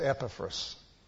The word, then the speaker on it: Epaphras: That's the right way to pronounce that. I always say Epaphras, but it's Epaphras.